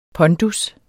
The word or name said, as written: pondus